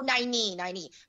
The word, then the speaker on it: Nynaeve: And, and there is a great line in there where one of the characters, Naive or Naive, what I can't pronounce her name. – Naive. – You're their lapdog. – Naive. – Oh, Nynaeve.